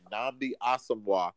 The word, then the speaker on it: Nnamdi Asomugha: We, we, let's pronounce his name again. Nnamdi Asomugha.